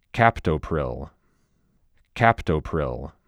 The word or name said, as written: Captopril